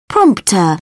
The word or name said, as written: prompt